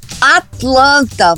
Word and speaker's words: Atlanta: Atlanta vai cair naquele T que o americano vai simplesmente pronunciar e o estrangeiro não vai ouvir. Atlanta. O primeiro é o stop T, que é aquele que você coloca a ponta da língua no alvéolo e segura, que eu chamo de T de Atlântico, de atleta, tá vendo?